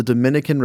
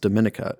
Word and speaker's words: Dominica: Last fun fact, it's the Dominican Republic, but the other island is not pronounced Dominica, it's pronounced Dominica.